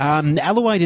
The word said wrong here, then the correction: Elodie: is up next, I believe. – After is Elodie.